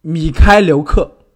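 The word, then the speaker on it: Mykhailiuk: 就是这个，我尝试一下，叫米凯哈留克吗？他这个名字到底是怎么读？ – 米开留克，他那个 H 应该是不发音的。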